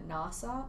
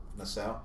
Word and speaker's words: Nassau: Miami, Florida, Nassau? No. – Nassau.